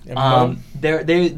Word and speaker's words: Embo: You see the bounty hunters, and I'm going to mispronounce this terribly, but Cad Bane and Imbo, E-M-B-O? – Imbo.